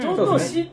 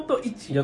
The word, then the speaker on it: 上等一: 上等市って読みそうになるんですけど、上等一、ね。上等一ですね。